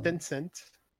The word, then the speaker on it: Tencent: Tencent. – Ça s'écrit comment? – T-E-N-C-E-N-T.